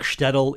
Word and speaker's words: Shtetl: The book Shtetl in the Sun. – I said it right. – Yes, you did.